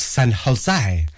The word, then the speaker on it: San Jose: Well, I think it's pronounced San Jose. You have to do something with your H's, don't you, with Spanish? I've never quite mastered that.